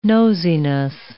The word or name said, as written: nosiness